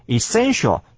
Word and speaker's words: essential: 注意到名词的后面那个 essence 呢重音在第一个音节，我们念作 essence，但是这边的形容词呢我们念作 essential。来，